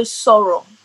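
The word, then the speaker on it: sọ̀rọ̀: So sorrow. – Sorrow. How do we spell that? – S-O-R-O. – S-O-R-O.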